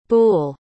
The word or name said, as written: boole